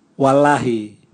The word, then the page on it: walahi: Raga